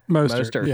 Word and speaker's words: Mostert: Morissette, is that how you say his name? They said it so much during the Browns game, it made me Mostert. – Sick. Mostert, yeah. That's it.